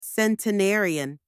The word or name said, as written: centenarian